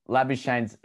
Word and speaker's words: Labuschagne: Labuschagne's English, but it's actually pronounced Labuschagne.